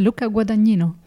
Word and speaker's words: Luca Guadagnino: It's Luca Guadagnino. – Luca Guadagnino.